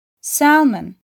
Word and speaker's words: salmon: L harfi ise genellikle A harfinden sonra geliyorsa telaffuz edilmez. Half, comb, salmon ve talk kelimelerinde olduğu gibi.